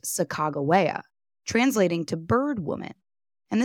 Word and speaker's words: Sacajawea: today I'm opting to say her name as some of her contemporary Shoshone family members pronounce it, Sacajawea. Sacajawea was a Lemhi Shoshone woman born around 1788 in Idaho's Lemhi Valley along the Salmon River.